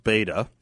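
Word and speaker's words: betta: Tina in Star Valley writes, Beta, pronounced Beta, that's funny, are Siamese fighting fish.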